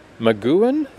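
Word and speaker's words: McGoohan: The series featured Patrick McGowan, is that how you say his name? – That's how I'd say it.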